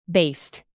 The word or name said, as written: baste